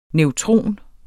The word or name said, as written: neutron